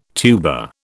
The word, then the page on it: tuba: British English